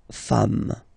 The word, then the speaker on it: femmes: Femme, you write it like it was at the singular form and then you just add this S at the end, and as we said, you don't pronounce it, so you get des femme, okay?